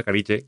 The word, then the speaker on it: Carige: Se llama, yo no lo conocía, la verdad, Carige, o cómo se pronuncia esto, o es en italiano. – Se llama Carige.